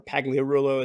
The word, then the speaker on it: Pagliarulo: And I applied at Ionstorm Austin because my good friend Emil Pagliarulo, or Pagliarulo as he pronounces it, was out there at Ionstorm Austin.